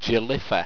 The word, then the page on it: jelifa: Owstwecian